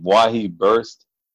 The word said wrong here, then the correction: Maui Burst: So I guess it's a Hawaiian name. It's a pineapple flavor. – It's Maui Burst.